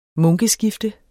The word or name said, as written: munkeskifte